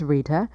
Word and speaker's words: Rita: My name is Rita, and that's spelled R-I-T-A, and Rochelle is spelled R-O-C-H-E-L-L-E.